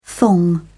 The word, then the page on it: thong: British English